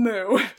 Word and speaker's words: Mooed: So it's spelled like mood, like... – Like mood, like a cow does, yeah. – Not just so, like big mood, but like mood, like a... – M-O-O-E-D.